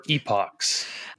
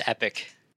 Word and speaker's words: epoch: I got to ask you, I've been trying to get a consensus with other people, but are you pronouncing it epic or epochs? – I'm one that pronounces it as epic. – Okay. Is there quite a bit of fluctuation with how people pronounce that? – I think it's an international fluctuation. So I think most US people will say epic, but I think epoch is.